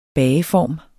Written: bageform

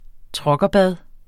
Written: truckerbad

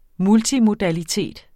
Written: multimodalitet

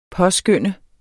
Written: påskynde

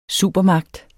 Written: supermagt

